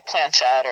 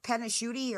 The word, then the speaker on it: planchette: What's the difference between holding a pendulum and touching a planchette? Or that's how you say it. – Yeah. – That's another thing I always say wrong. I call it the panachute or panachute.